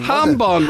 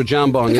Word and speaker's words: jambon: I think ham we looked it up at the time and it is hambon. – There you go. – Yeah, beg your pardon. – Is it still spelled with the J? – Yeah, but you don't pronounce it like, you know, it's like you have to go, huh, it's a hambon. That's all it is.